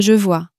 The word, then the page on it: je vois: french